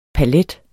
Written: palet

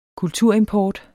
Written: kulturimport

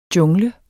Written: JUNGLENS

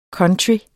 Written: country

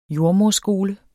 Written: JORDSMONNET

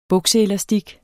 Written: bukseelastik

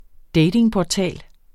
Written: datingportal

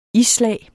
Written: isslag

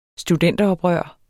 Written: studenteroprør

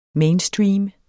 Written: MAINSTREAM

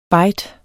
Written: byte

